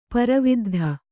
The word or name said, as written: ಪರವಿದ್ಧ